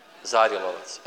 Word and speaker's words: Zarilovac: Evo kako mi je bilo fora danas, bio sam u ovome Zarilovac, jes tako izgovara. Jel, Zarilovac ili Zarilovac? Zarilovac, evo.